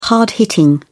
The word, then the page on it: hard-hitting: British English